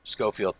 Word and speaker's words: Schofield: Schofield. Yeah, that's what it is. – There you go. – Yep. S-C-H-O-F-I-E-L-D. – Schofield. – I think that is. Yeah. Schofield.